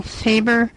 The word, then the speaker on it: Faber: But it's pronounced Michelle, and Faber is F as in farsight, F-A-B as in boy, E-R.